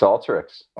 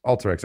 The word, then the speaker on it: Alteryx: Then you went to Alteryx. Alteryx, or I feel like I always mispronounce it. – It's Alteryx. – Alteryx, okay.